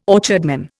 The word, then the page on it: orchardman: Indian